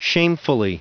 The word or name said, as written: shamefully